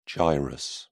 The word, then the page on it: gyrus: British English